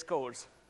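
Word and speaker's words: CORS: The other thing that we're announcing is cores. Yes, it rhymes with doors.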